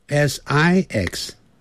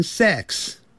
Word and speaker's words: six: 那个 v 是有声音的，不过真正美国人在念这个时候，他是念成没有声音的 five five，要把 v 念出来的时候，他停住了 five five，但他不会念成 five five five five，六 s i x six eighteen。 It 二三四 it 短的元音 six six，很多人会念成 sex， sex 是性，性别那个性，好 sex 那是 s e x，所以六啊要念成 six six seven 七 s e v e n seven ven 那个 v。